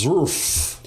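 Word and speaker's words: Zrfff: Zrff. – Zrff. – That's Z-R-F-F-F. The third F is silent.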